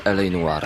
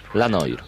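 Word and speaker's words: L.A. Noire: Dlatego y, nie czyta się Noir, tylko Noir to dla tych wszystkich, którzy cały czas nie wiedzą, jak zapytać w sklepie o tę grę. La Noir. Nie. LA Noir. Z francuskiego troszeczkę.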